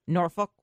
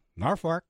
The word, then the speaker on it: Norfolk: People say we are saying Norfolk wrong. Yeah, that's not the right you say it. – Norfolk? – It's pronounced Norfolk is what I've received. – Norfolk. – There's no R after Norfolk. – Nor. – No, no forks with dinner. – It's F-O-L-K.